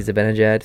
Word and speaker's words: Zibanejad: Mickey Zibanejad, 3 games played, 5 points.